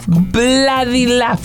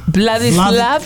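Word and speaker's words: Władysław: Ladislav. Vladek. – Vla. Como ve corta. Tú me dices que no se dice. – Sí, ve corta. – Vladislav. – Vladislav Spilman.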